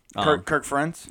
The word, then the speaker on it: Kirk Ferentz: Kirk Ferentz? – Yes.